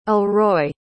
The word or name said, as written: alroy